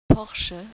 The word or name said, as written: Porsche